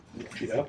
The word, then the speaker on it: lucida: So if you can sort of imagine that the word obscura in Italian means dark, you can sort of figure out that lucida or lucida, lucida properly pronounced in Italian, lucida would be light.